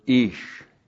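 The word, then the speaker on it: ish (איש): and then we have the word ish. Now all you males out there, you are ish. There you go. Aleph, long I, S, H.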